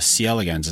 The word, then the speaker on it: C. elegans: And in this case, the relationship between neurons and the C. elegans. Is that the right way to say it? – Yeah, exactly. And I would say more broadly,